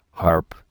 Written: harp

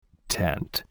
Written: tent

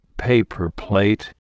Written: paper plate

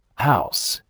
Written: house

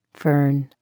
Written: fern

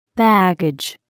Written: baggage

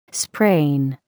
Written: sprain

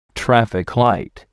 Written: traffic light

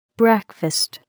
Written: breakfast

